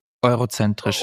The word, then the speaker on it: eurozentrisch: Aber die meisten Karten, die wir haben, ja, die wir sehen, sind ja europazentristisch oder wie das ausgesprochen wird. – Eurozentrisch, ja.